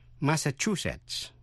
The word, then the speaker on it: Massachusetts: Massachusetts. Massachusetts. Nhấn mạnh vần thứ ba.